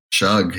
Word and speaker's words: Shug: I'd like you to maybe talk about, you got a Miles, is it Shug? Is that how you pronounce Miles' name? – Shug, yeah, Shug.